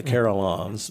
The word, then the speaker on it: carillons: Am I pronouncing it right? – Yes, the carillons.